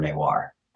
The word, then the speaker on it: Newa: Newar is called Nepal Bhasa, like the Nepal language. And the word Newar, which is spelled Newa in how it's pronounced in Newar, probably derives from Nepal, which is, of course, the place.